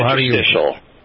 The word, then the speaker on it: interstitial: Am I pronouncing that, right? Interstitial? – Interstitial. – Interstitial. – Okay. Close enough. Interstitial.